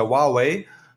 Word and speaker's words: Huawei: beziehungsweise ausgesprochen wird, ist ja Huawei.